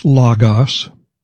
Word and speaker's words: logos: The Greek word is pronounced logos.